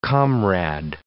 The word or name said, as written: comrade